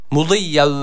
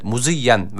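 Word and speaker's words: مضيا: Burada yine dat harfi var. Mahreç olarak zorlandığımız bir harftir. Dikkat edelim. مُضِيَّا وَلَا يَرْجِعُونَ evet, مُزِيَّا veya مُضِيَّا şeklinde de yine okunmasın inşallah.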